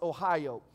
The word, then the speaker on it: Ahio: even though it's spelled A-H-I-O, it's still pronounced Ohio.